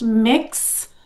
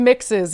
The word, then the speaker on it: Mx: MX, which I guess is pronounced mix instead of miss or misses,